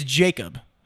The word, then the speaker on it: Jacob: It's Jacob. It's just J-A-C-O-B. Yes, but people think it's Jacobs all the time because most people don't have a last name of Jacob.